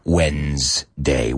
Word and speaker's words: Wednesday: Y si sabes pronunciar cuando y le añades una S, pues ya tienes la palabra Wednesday, Wednesday. Bueno, ese sonido de Z que usamos mucho en easy, Wednesday, no es un sonido que usáis en español.